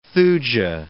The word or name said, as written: thuja